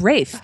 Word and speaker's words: Ralph: How come Ralph Fiennes is pronounced Ralph Fiennes when his name is Ralph? – I have no idea, but it's Ralph. I know. It's Rafe. Well, because it's like salmon. It's S-A-L-M-O-N.